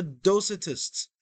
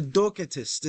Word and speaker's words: Docetists: One sort of mnemonic device, perhaps, that can help you with recalling this, is the ancient Greek sect, the heretical sect called the Docetists, or the Docetists, if you want to say it like that.